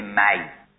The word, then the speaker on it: می: بعضی اوقات دواها به احتیاج به داغ کردن آخر و دوا الکی به صوت قمری و بلبل اگر ننوشی می، حالا بعضی میگن باید تلفظ کنیم می، استادای ادبیات همه جا دیدم این یک فتحه رو میذارم، بابا هیچ فارسی نمیگه می، همشون میگن می.